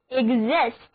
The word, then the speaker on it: exist: 我们首先来看这个单词 exist，是在表示站立的 exist 词根之前加上了表示出的 ex 前缀，那么单词 exist, E-X-I-S-T, exist. 为了避免重复，把词根开头的 S 字母省略，单词就读作 exist.